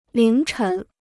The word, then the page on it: 凌晨: líng chén